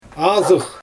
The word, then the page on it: azyx: Xyzyl